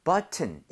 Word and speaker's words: button: 이거 발음할 때 여러분들 네. – 앞쪽에 강세를 엄청 주셔야 돼요. 그래서 여기 강세를 많이 줘서 button 이것도 돼요. 되는데 원어민들이 앞쪽에 강세를 많이 주면서 키를 그냥 거의 없어지게 만들 때가 있어요.